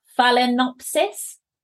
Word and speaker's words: Phalaenopsis: Phalaenopsis. – Uh-huh. Yeah. – Did I say it right? – Yeah.